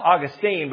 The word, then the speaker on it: Augustine: It's spelled Augustine, but it's actually rightly pronounced Augustine. So it should be St. Augustine, Florida.